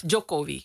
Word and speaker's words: Jokowi: En dan heb je Janowi, zeg ik het goed? – Jokowi.